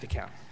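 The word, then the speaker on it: Descamps: Now, looking at, is it pronounced DECOMP? DESCOMP? – DECOMP.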